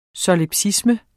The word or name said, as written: solipsisme